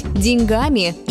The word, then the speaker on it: деньгами: И из-за этого возникает ошибка в обычной речи, ведь правильно говорить «деньгами» и «о деньгах». Почему так происходит? Слово «деньги» в начальной форме действительно с ударением на первом слоге. И по инерции его пытаются сохранить во всех формах. В обычной речи действует другое правило. При изменении формы ударение уходит к окончанию.